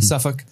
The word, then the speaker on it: Suffolk: We're in Suffolk. Am I saying that pronounced? – Suffolk. – Suffolk. – Suffolk. Why is there a fucking L in there? – Suffolk.